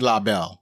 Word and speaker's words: LaBelle: If you just say Belle, you know, so it's LaBelle. So all I did, I think it's French, is put the E on the end. The E is silent, It's LaBelle. It's not only is it silent, but it doesn't affect the first E.